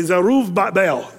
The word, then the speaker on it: Zerubbabel: Now, if you're a Jewish guy, you'd say Zerubbabel. That's the way you'd say his name.